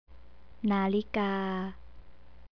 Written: nalika

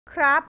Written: krab

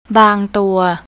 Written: bangtua

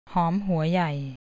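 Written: หอมหัวใหญ่

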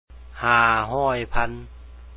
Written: 500000

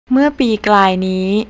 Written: mearpeeklay

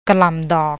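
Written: กะหล่ำดอก

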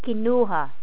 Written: kinuha

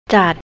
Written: jud

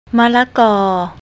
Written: malakor

